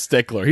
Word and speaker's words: Stichler: It's spelled S-T-I-C-H-L-E-R, so it might not be Stickler, but like I think it's Stickler. No, it definitely is. And that's even more principal. Like it'd be like, look, it's D-Y-C-K-B-U-T-T-E.